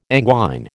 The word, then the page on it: anguine: American English